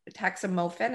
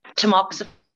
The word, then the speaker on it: Tamoxifen: And then the tax is it taxam taxamofen? Is that how you say it? – Tamoxifen.